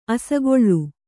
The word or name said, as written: ಅಸಿಗಲ್ಲು